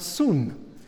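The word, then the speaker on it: soon (σύν): That word sync comes from the same word that Paul uses here. In the Greek, it's S-Y-N, not S-I-N. Syn, or in Greek we usually pronounce it soon, which is translated into English with.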